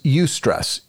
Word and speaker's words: eustress: The term for good stress is eustress. It's spelled E-U-S-T-R-E-S-S. Eustress.